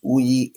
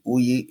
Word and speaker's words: Uyi: I'm also on all social medias from Facebook, Instagram, LinkedIn, and it's Uyi Abraham. My first name, Uyi, is a three-letter word, and it's spelled U-Y-I. It says U-Y-I Abraham.